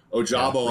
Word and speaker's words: Ojabo: Obviously on the other side, you have Hutchinson, you have a Zigbo on the other side, like Ojabo on the other side. I'm going to pronounce names correctly today. Ojabo on the other side that can provide pressure.